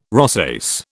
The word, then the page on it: rosace: British English